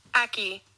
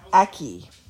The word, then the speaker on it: ackee: Aki. – Yep, I was close. Aki.